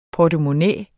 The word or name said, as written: portemonnæ